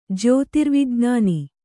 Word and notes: ಜ್ಯೋತಿರ್ವಿಜ್ಞಾನಿ: jyōtirvijn`āni

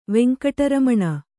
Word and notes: ವೆಂಕಟರಮಣ: Kannada

